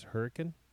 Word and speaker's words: Hurricane: So going back to saying things regionally, there is a town in Utah that's spelt hurricane, but it's pronounced Hurricane.